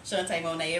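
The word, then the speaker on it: Shantae Mone't: Shantae Monet, it rhymes.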